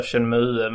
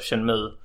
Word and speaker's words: Shenmue: Jag köpte PlayStation 4 nu för att Shenmue. Många står sig på att det är Shenmue, men det heter ju Shenmue.